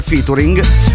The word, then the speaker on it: featuring: E a proposito di featuring, vorrei dirvi che io so benissimo che si pronuncia featuring,